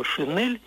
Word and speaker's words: шинель: В минувшее воскресенье в интерактивной передаче по радио «Петербург» слушательница сказала, что в 70-е годы в ее школе за произношение слова «шинель» ставили двойку. Надо было произносить именно «шинель».